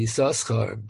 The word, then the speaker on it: Yisoschar: He's known as Bnei Yisoschar because that was his chiddush that you have to pronounce. You have to pronounce the both the sins. We usually assume that it's a kriya ksiv, even though it's written with two shins, you only pronounce one of them.